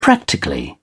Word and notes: practically: British English

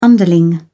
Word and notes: underling: British English

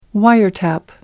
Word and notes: wiretap: American English

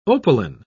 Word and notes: opaline: British English

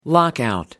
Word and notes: lockout: American English

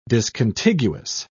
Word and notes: discontiguous: British English

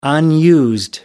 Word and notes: unused: American English

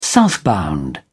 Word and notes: southbound: British English

